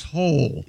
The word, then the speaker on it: Hole: Detective Ole. – Yeah. – Ole. – But it's pronounced Hole. – Yeah. – Hole? – Detective Hole. Yeah. – It's spelled H-O-L-E.